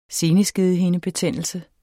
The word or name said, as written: seneskedehindebetændelse